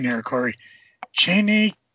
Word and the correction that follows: Cheyenne: Kid, if that sounds right. – The Cheyenne Kid? – Cheyenne, that's it. See, I don't even know this guy. Yes, Cheyenne, Cheyenne.